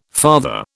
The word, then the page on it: father: British English